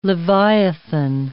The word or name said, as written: leviathan